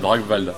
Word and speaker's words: Drakkwald: Alors, alors même, c'est, même en fait nous on prononce ça Drakkwald avec un V. – D'accord. – Donc on se W comme un V à l'allemande en fait.